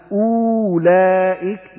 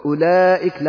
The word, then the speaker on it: أولئك: لا تقول أولئك، لا قل أولئك، لا تنطق الواو، مثل قالوا، الألف التي بعد واو لا، هذا للدلالة على أن هذه الواو واو جمعة.